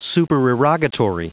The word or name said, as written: supererogatory